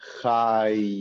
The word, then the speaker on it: chai: Now, the yud over here is pronounced because at the end of the word, we, like I mentioned earlier, we kind of mentioned the y, the y of the yud a little bit. So this word is pronounced cha y, chai.